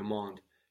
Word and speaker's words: mon: And the word for my, my, is mon, M-O-N, mon, Mon. – mon, mon. So again, we're not pronouncing the consonant on the end, but this isn't just any old consonant, no, it's an N, which means we also swallow it into the vowel and make it more nasal, mon.